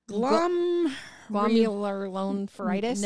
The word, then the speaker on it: glomerulonephritis: I don't know how to pronounce it. Do you? – Glomerulonephritis? – Nephritis. – Yeah.